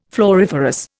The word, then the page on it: floriferous: Indian